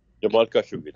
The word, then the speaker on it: Khashoggi: And I'm pronouncing you Jamal Khashoggi. Is that correct? – Jamal Khashoggi, that's correct.